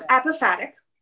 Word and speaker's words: apophatic: And anyway, could you explain what this apophatic, I can't pronounce it. – Apophatic. So it's apophatic.